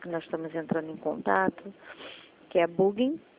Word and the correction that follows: Bunge: Ou é bug? A pronúncia correta. Bug. – É bung, bung. – É bug, tá.